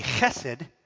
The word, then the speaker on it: chesed: Chesed, or pronounced correctly, would be chesed, is probably the most significant Hebrew word in the Old Testament.